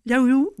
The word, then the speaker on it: Liao Yiwu: Liao Yiwu, voilà.